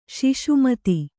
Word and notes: ಶಶಿಮತ: śaśi mata

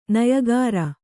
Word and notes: ನೆಯಿಕಾರ: neyikāra

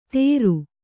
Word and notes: ತೇರು: Kannada

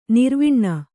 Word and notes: ನೆರೆವನೆ: nerevane

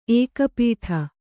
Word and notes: ಏಕಪೀಠ: ēkapīṭha; Kannada

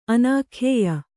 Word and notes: ಅನಾಖ್ಯೇಯ: anākhyēya

